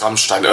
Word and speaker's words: Rammstein: Es heißt Rammstein.